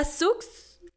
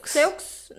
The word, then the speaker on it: Seux: Después tenemos a Wanda Sux Seux. Seux. No, es que la X en francés no se pronuncia.